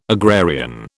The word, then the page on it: agrarian: British English